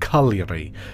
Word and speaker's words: Cagliari: Now, this is spelt Cagliari, but I believe it's pronounced Cagliari.